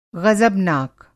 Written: ग़ज़बनाक

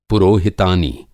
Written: पुरोहितानी

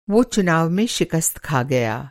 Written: शिकस्त

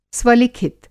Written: स्वलिखित

